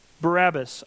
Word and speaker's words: Barabbas: So, Mark clues us into this fact, and then says the man named Barnabas had been imprisoned. Who's Barnabas? Sorry, Barabbas. I'm mispronouncing it. My apologies. Barabbas.